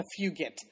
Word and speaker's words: Fugit: I think it's actually a hard G. It's a Fugit.